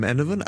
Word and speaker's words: Enevun: I'm Enovan.